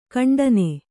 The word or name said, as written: ಖಂಡನೆ